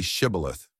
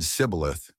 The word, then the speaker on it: Shibboleth: When he answered, "I am not," they replied to him, "Now say Shibboleth." If he said Sibboleth, because he was not able to pronounce it correctly, then they took hold of him and slew him at the fords of the Jordan.